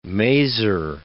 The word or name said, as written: mazer